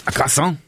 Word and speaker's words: croissant: No, why are you pronouncing the R? – Go on then. – You just said croissant. – How do you say it? – Croissant. – Okay, that's good.